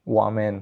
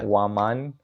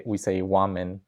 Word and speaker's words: Wuaman: Wuaman. – Wuaman. – And if it's like with the Spanish accent, Wuaman. Uh-huh. But here, I mean, we say Wuaman. – Wuaman. – It's easier to pronounce.